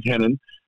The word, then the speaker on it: Renan: And when you see it in America, it looks like Renan. But in Portuguese and Brazil, the R's are pronounced with an H.